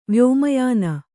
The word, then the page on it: ವ್ಯೋಮಯಾನ: Kannada